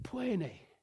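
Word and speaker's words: pâine: And the little kid that was with me, he said, plene. Plene. My, you know, southern language. And he said, no. Plene.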